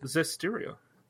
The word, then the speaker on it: Zestiria: Zestiria.